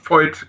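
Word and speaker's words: Voith: aber Void oder wie spricht oder Void, wie spricht man das aus? Void, genau.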